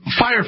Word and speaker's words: Fyre: Fire Festival. It's spelled F-Y-R-E.